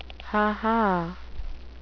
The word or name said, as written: ha-ha